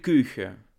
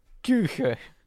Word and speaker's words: Kuchen: Vrouw Boltes Koegen. Koegen? Koegen? Zeg ik dat goed? – vrouw ehm, Boltes Koegen. – Koegen. Of eigenlijk Kuchen. – Kuchen. Vrouw Boltes Kuchen.